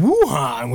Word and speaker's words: Wuhan: Which actually rhymes with Wuhan, which is one of our topics today. – Wuhan? Oh, Wuhan, yeah.